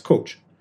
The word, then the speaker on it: Kocs: The word is actually the name of a town in northwest Hungary, K-O-C-S, pronounced as coach.